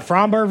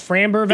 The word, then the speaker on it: Framber: We were just talking about Fromber versus Framber Valdez.